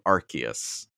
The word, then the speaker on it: Arceus: But yeah, so because arse means butt in the UK, Pokemon has changed the pronunciation to Arceus.